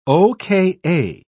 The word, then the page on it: oka: British English